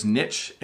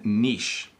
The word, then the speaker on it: niche: So first of all, there are two pronunciations. There's niche and niche, okay? And we use both in Canadian English.